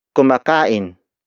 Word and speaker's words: kumakain: Kumakain si Chris dito. Kumakain si Chris dito. The first word is kumakain. Kumakain. Kumakain. Kumakain is spelled as K-U-M-A-K-A-I-N.